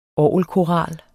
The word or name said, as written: orgelkoral